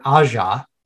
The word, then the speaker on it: Aja: So it has him, who he pronounced his name as Alexander Aja. – Aja, okay. – So how it's read.